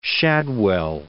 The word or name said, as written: Shadwell